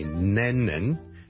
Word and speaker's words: Lennon: 第一個字呢就是人名來的 John Lennon。 John Lennon。 John Lennon, 一個英國的很出名的歌手作曲家。 Lennon。 L-E-N-N-O-N。第一個是 L 音，所以不要將它讀成 N 音，不是 Lennon, 是 Lennon。 Len 后面嗰个先系 N 音，所以 Lennon 仲要两个 N 音添。